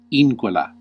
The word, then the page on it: incola: /ˈin.ko.la/; Classical